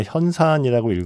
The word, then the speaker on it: 현산: 물론 이게 또 정확하게는 자산이 아니라 현산이라고 읽어야 한다고도 하죠.